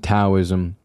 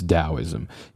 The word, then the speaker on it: Daoism: Regardless of how you spell it, it should always be pronounced with a D sound. So it isn't Taoism, it's Daoism.